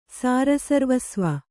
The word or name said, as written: ಸಾರಸರ್ವಸ್ವ